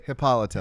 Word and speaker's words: Hippolyta: No, and then we could have like and Frank could be like Donna Troy, you know, and Terry could be what, Hippolyta or whatever her name is. Hippolyta. I can't say her name. – Oh my god. – What is it? – Hippolyta. – Hippolyta. There you go.